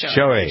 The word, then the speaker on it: Shohei: Shohei.